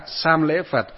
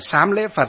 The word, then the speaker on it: sam lễ: À, hiệu là gì đó, là sam lễ Phật, sam lễ pháp và sam lễ tăng, hay có nơi thì đọc là sám lễ Phật, sám lễ pháp và sám lễ tăng. À, cũng được, nhưng mà ở trong cái từ chữ Hán nó gọi là sam.